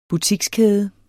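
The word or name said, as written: butikskæde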